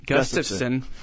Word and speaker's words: Gustafsson: And I think it's interesting to see how, because, I mean, both those guys, Gustafsson, I can't even say his name. – Gustafsson.